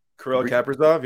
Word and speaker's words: Kirill Kaprizov: There was a couple, the Kaprizov, or however you say his name. – Kirill Kaprizov, yeah.